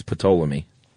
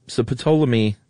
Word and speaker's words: Ptolemy: So Ptolemy, which has got a silent P, by the way. – Yeah, it's a cool name. – Which is why it's such a great name. It's Ptolemy.